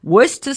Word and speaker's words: Worcester: Oh, I have to say it again. Worcester sauce. I've said it right.